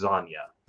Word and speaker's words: Zanya: It was nice to hear him say Zanya, because I was able to hear how the guy who created the character pronounces the name. It's Zanya.